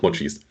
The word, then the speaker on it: Mochis: Da gibt es dann immer die äh, leckeren äh, Mochis. Habe äh, ich das richtig gesagt? Doch, Mochis.